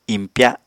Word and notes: impya: apatani

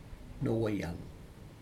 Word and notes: noweag: Aneityum

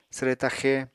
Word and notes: swre taxe: apatani

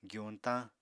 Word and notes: gyuñtañ: apatani

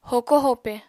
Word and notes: hoko-hope: apatani

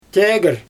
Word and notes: teger: Xyzyl

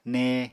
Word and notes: ne: Apatani

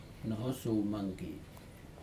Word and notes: nohos umangi: Aneityum

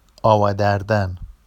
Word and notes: ɑwa dardan: Vafsi

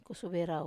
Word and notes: kusuvei rau: wamesa

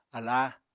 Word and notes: aláa: apatani

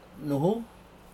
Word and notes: nuhu: Aneityum

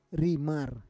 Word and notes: rimar: Roon

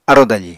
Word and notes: arò dafi: Apatani

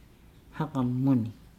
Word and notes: hagamuni: Nukuoro

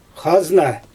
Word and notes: xazna: Xyzyl

